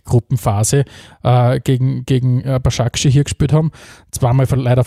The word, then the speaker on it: Başakşehir: Bascha Keshia. Başakşehir. Başakşehir.